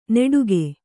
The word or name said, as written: ನೆಡುಗೆ